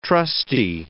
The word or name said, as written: trusty